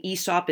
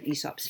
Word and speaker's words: Aesop: He also mentions Aesop's fables, one of Aesop's fables, and I'm saying Aesop. It's spelled AESOP, the way that I learned to hear it, which was Aesop. I've looked it up. You sometimes see it, hear it as Aesop.